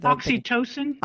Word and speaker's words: oxytocin: Oxytocin. – Oxytocin. Oh, my God. I'm so fucking dumb. I said it wrong 10 times.